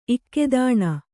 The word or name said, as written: ಇಕ್ಕೆದಾಣ